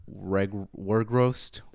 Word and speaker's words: Weghorst: I believe he's called Weghorst. – That was perfect. Weghorst. Never heard it better.